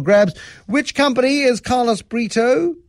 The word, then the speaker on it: Brito: Carlos Brito. – Yes, rhymes with Frito.